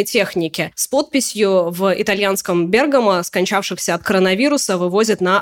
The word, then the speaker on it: Бергамо: Ты знаешь, я тоже так всегда думала, но Википедия утверждает, что именно Бергамо, и, кстати, наш корреспондент в Италии тоже говорит именно с таким ударением, поэтому я решила себя по поправлять ну, постоянно.